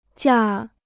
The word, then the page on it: 驾: jià